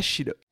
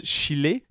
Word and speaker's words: Schiller: Schiller. Un point amusant d'ailleurs, elle prononce Chile, ce qui est incorrect, mais il est vrai plus mélodieux.